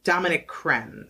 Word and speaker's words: Dominique Crenn: And the only and the only female chef to have earned three Michelin stars in the United States, and that is Dominic Shen, I believe is how you say no Dominique Crenn.